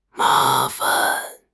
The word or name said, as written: ma2 fan5